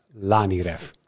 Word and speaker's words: Laniref: Laniref spreek je uit als Laniref. Nou, althans, deze... dat zegt Laniref zelf.